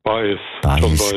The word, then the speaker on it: Baez: Sagt mir der Joan Baez oder Baez? – Baez, gell, ja. – So. – Latinisch ausgesprochen.